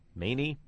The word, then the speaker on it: Meni: M-E-N-I. Many.